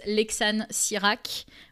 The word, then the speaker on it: Lixen Sirac: My tag is impossible to pronounce in English, so I'm going to say it with a French accent. Sorry, it's Lixen Sirac, which is my full name.